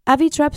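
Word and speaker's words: Avytrap: Avitrap se escribe A-V-Y, trap de trapeo, de T-R-A-P.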